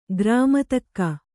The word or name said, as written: ಘರ್ಮೋದಕ